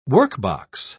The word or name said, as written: workbox